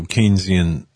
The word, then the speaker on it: Keynesian: And I know Keynesian economics. I know where that came from, John Maynard Keynes. But I find it interesting that it rhymes with Keynes.